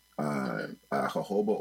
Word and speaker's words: jojoba: So jojoba oil, it usually gets mispronounced as jojoba.